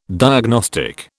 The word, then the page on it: diagnostic: British English